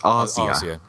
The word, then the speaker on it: Asia: I think Daria Nicolodi, as is mentioned, was married to Dario Argento, and their daughter Asia Argento was in quite a lot of Argento's movies, and obviously he's... – I think it's pronounced Arzia. – Arzia, sorry. I think they were just about to break up, apparently, during the filming.